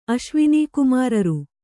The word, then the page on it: ಅಶ್ವಿನೀಕುಮಾರರು: kannada